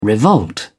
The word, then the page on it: revolt: British English